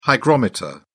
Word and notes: hygrometer: British English

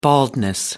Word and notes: baldness: British English

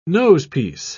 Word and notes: nosepiece: British English

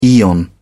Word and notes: aeon: British English

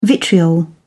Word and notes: vitriol: British English

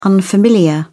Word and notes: unfamiliar: British English

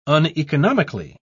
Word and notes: uneconomically: British English

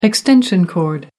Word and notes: extension cord: British English

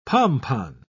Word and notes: pompon: British English

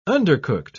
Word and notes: undercooked: British English

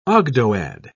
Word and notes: ogdoad: British English

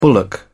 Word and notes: bullock: British English

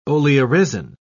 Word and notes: oleoresin: British English

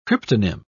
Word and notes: cryptonym: British English

